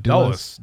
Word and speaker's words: Dulles: Dulles. – Yeah, I always overthink it. Washington Dulles International Airport after the former president.